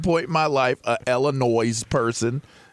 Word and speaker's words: Illinois: I mean, I'm just saying, you know, if it was Josh Illinois, everything would have worked out perfectly fine. – Silent S. – You know, the silent S.